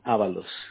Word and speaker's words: Avalos: No, just my name and last name, Avalos.